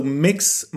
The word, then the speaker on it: Mx: MX, so Mix Martin, instead of Mr. or Mrs. we should call them Mix Martin.